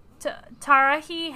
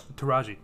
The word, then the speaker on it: Taraji: Tarahi Henson? – Taraji.